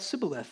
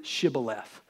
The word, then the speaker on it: shibboleth: What in the world is this shibboleth sibboleth thing? I had so much fun thinking about that this week. The word there should be pronounced in Hebrew shibboleth.